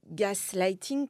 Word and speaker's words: gaslighting: Alors en, en, anglais, on dit gaslighting comme quelqu'un, euh, comme un, homme qui me mansplainait mon, livre, me, l'a fait remarquer récemment.